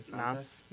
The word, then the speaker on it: Nantes: He was a winger for Nantes up in France. Did I pronounce that right? – Yeah, Nantes.